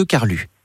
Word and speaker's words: Carlux: De Prades de Carlux. C'est comme ça que l'on prononce ? – On ne prononce pas le X, mais ce n'est pas grave.